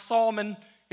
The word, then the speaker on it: Salome: In fact, if you pronounce her name, her name is pronounced Salmon. It looks like Salome in the scriptures.